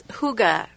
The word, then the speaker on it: hygge: And it's spelled H-Y-G-G-E. Hygge.